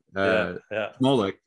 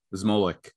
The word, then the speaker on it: Zmolek: Zmolek is, I think, how you properly pronounce it. But most of the time, you know, when I grew up in the States, right, it really just became Zmolek.